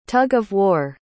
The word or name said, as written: tug-of-war